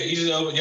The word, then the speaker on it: Idris Elba: So the person that I've seen that's usually usually dressed pretty good is Idris Elba. I guess that's how you pronounce it. – Yeah, Idris Elba.